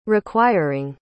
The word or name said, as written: requiring